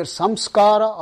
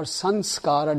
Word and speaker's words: samskara: That word is pronounced as either samskara or sanskara, depending on where in India you're from. If you're from the south, you will say samskara.